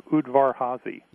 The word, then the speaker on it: Udvar-Hazy: Udvar-Hazy.